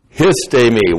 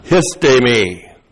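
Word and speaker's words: Histemi (ἵστημι): Histemi, what a great verb. I love this verb. H-I-S-T, long E-M-I. Histemi.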